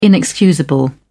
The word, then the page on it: inexcusable: British English